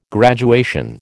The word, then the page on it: graduation: American English